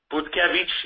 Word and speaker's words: Butkiewicz: please reach out to Marta Butkiewicz. Yes, she is Polish. And yes, I do speak Polish, so I can pronounce her last name. The W is a V sound.